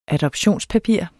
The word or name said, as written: adoptionspapir